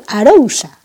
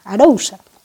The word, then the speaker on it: Arousa: Ogrove es un pueblo que está situado en las Rías Baixas, concretamente en la Ría de Arousa, Arousa, perdón, que no me sale muy bien a mí lo de Arousa, siempre digo Ría de Arousa porque en castellano se dice así.